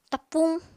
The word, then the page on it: tapuñ: Apatani